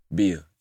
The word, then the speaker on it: Bia: How you pronounce her name? Is it Bia or B-I? – Bia. Yeah, Bia.